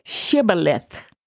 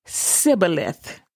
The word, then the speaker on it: Shibboleth: Ephraimites had difficulty pronouncing the word Shibboleth, the Hebrew word for river. When they were asked to say the word for river, they would pronounce it Sibboleth and were killed on the spot.